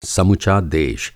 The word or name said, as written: समुचा